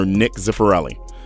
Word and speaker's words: Nick Zifarelli: Nicolino's at 2077 Pembina Highway, and in studio is owner Nick Zifarelli.